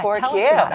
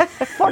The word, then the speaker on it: 4Q: So 4Q is what it sounds like. Say it like in New York. It's not the number four in the letter Q. It's not four quarters. It's 4Q. – And 4Q. You know, and the coffee. – 4Q. Say it like a full New Yorker.